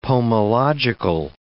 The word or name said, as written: pomological